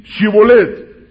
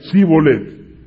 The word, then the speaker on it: Shibolet: Si él respondía no, entonces le decían: ahora pues, di Shibolet. Y él decía Sibolet. Y entonces, al no pronunciarlo correctamente, lo tomaban.